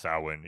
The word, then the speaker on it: Samhain: it's pronounced Samhain, even though it's not Samhain.